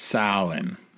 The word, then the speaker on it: Samhain: It's Samhain. So, but this festival is most closely linked to the origins of modern Halloween. And we're going to talk about a couple of things related to Samhain today.